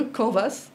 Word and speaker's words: Canvas: Donc le fameux Canvas, ou Canva, selon comment vous voulez le prononcer, qui a 9 blocs.